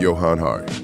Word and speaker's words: Johann Hari: So that's why we have these quick fixes, and here's more with Johan Hari.